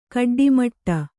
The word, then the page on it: ಗೊಡ್ಡಮಟೆ: goḍḍamaṭe